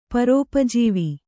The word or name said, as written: ಪರೋಪಜೀವಿ